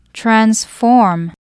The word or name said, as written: transform